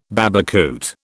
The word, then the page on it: babacoote: British English